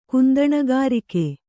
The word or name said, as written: ಕುಂದಣಗಾರಿಕೆ